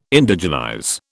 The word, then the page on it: Indigenize: British English